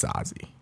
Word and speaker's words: Zazie: I'm pretty sure it's Zazie. – Bet. I think it is Zazie. – Yeah. – I think, yeah, you're 100% right.